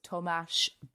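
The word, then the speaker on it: Tomáš: I've also got Thomas Burditch, which, you know, that's how I say it, Bert Thomas Burditch. Some people say Burdick. Apparently, the correct way to say it is Tomash Berdicht.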